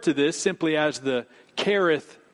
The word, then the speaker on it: Cherith: Now, Kereth, some would pronounce it Cherith. It is spelled with a C-H as you see it there. But I think the correct Hebrew pronunciation is Kereth, as if it were to say K-E-R.